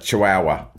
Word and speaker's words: chihuahua: Some as big as maybe a chichuana, right? But not a chichuana, a chihuahua.